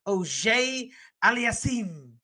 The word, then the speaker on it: Auger-Aliassime: That's why I'm rooting for some Canadians like Felix Auger-Aliassime.